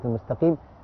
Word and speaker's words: المستقيم: طيب في ناس تقول المستقيم، ينفع؟ لا، المستا، يعني نرقق السين والايه والتاء. ننطق كلمه المستقيم، المستقيم. خلي بالك، والقاف مكسورة، تنزل القاف تفخيمها، ينزل درجة أخيرة من التفخيم، اسمه تفخيم نسبي.